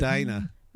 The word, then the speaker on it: Medina: Medina.